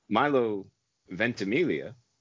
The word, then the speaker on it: Milo Ventimiglia: Moving things along, Milo Ventimiglia.